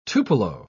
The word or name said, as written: tupelo